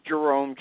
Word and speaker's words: Jerome: I do, but best for anybody to go on my Facebook page, and the last name is spelled, first name is Jerome, J-E-R-O-M-E, last name is spelled H-U-Y-L-E-R,